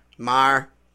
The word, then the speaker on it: Maher: Maher. Bill Maher. – Fuck you to Bill Maher. – If you're going to say fuck him, you got to pronounce his name.